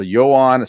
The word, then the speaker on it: Joan: So Johan, spelled like Joan, J-O-A-N, Adon from Washington.